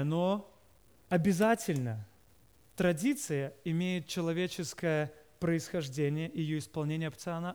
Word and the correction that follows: опционально: опциально, опционально. Опционально. Простите, пожалуйста, ударение поставил не там, где надо.